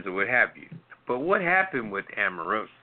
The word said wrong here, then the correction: Omarosa: if I'm saying her name right, is it Amorosa? – I think it's Omarosa. – Omarosa.